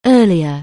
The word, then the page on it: early: anglais